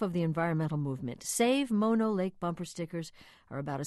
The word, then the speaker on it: Mono: Joanne Michella of the Watsonville Health Center writes, "It's not Mono Lake, it's Mono, named for the Mono Indians, the hardy native folk who lived off the brine shrimp in the lake for generations.